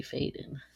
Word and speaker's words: Faden: Faden. – I'll spell it for everyone. Brayden _ _FADEN. So Brayden and Faden are direct rhymes because you changed the spelling.